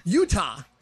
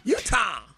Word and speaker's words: Utah: Or as you say, Utah. – Yeah, what do you do there? – How do you say the state? – Utah. – Okay. – Utah.